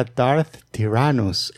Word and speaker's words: Darth Tyrannus: Dark Tyrannus. – Dark Tyrannus. – Ajá. Ahí va. – Era Darth Tyrannus.